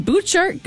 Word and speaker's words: Butchart: A-R-T. – Oh, you got really close. – So close. It's like it's got a silent T or something. – Yeah, B-U-T-C-H-A-R-T.